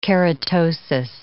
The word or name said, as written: keratosis